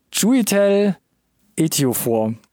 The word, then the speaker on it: Chiwetel Ejiofor: Chiwetel Ejiofor. – Komm, das klang jetzt nicht so richtig souverän, aber 90%, es war, glaube ich, technisch gesehen erstmal richtig. – Probierst du es mal, komm. – Chiwetel Ejiofor.